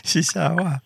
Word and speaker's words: chihuahua: Chihuahua.